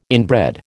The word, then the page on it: inbred: American English